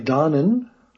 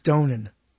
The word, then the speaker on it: Donen: I think it's Donen. I think he pronounces it Donen.